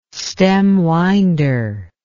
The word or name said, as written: stemwinder